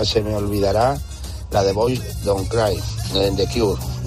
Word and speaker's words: Boys Don't Cry: No sé si lo he dicho bien, pero bueno, creo que lo habéis entendido. – otra de las canciones eh, míticas de, que forma parte de la, de la, historia de muchas, de muchas personas, de la historia de la, de la música, en este caso de Cure con Boys Don't Cry. Lo ha pronunciado de maravilla Carlos de Toledo. Perfectamente.